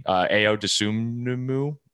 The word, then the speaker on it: Ayo Dosunmu: Ayo Dosunmu, I don't know. It's kind of like the word anemone, but that guy from Illinois.